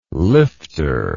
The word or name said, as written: lifter